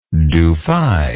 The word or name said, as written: Dufay